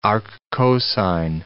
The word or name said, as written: arccosine